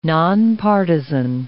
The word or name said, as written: nonpartisan